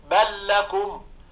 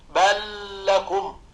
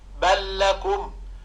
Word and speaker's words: بل لكم: انظر مثلا عندما تنطق كلمة الذي، فعندما تنطق بل لكم مثل هذه اللام تماما في النطق فتقول بل لكم. وهنا نسمع بعض الناس وهم يقرؤون كأنهم يغنون اللام فيقولون بل لكم، هذا خطأ، إنما هي لام مشددة تقول بل